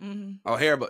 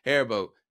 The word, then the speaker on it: Haribo: Because everybody be talking about Haribo. I think that's how you say it. – Haribo. – But Haribo. Excuse me. Yeah, Haribo. But not a lot of people know how great Albanese is.